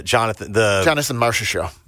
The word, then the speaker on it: Marchessault: Mark Chusola, Jonathan Marchessault. – Marchessault, yeah.